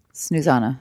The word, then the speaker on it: Susanna: Susanna.